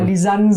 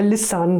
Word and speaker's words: Lisanne: Und da hat Tom Finn geleitet ein Cthulhu-Abenteuer für den Carsten Steenbergen, für die Lena Falkenhagen und für die Frau, deren Lisanne Namen ich ja mal falsch ausspreche, aber Lisanne Surburg. Dankeschön. Ich mache irgendwie immer Lissan draus und das tut mir sehr leid.